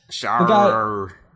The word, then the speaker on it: Shahriar: No, well, Scheherazade, yeah, but the king, I mispronounced it, Shahriar, I guess? – Shahriar. – Yes, the king who kills every wife, he marries a new woman every day and then kills his wife the next morning.